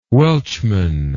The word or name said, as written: welchman